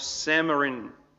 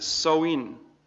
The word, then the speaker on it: Samhain: And that's actually not how you pronounce it. You pronounce it Savan or something like that. And apparently the neo-pagans, because it was a pagan festival, and a pagan is someone who believes in multiple gods, they pronounced it Soin or Awin. There's a lot of different pronunciations.